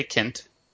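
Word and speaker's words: Kindt: How do you say his name? Is it long I, short I? – I say Kint.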